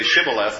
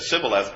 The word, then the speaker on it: Shibboleth: They were supposed to say Shibboleth, and if they said Sibboleth because they couldn't pronounce Shibboleth, then they ended up losing important portions of their life, like all of it.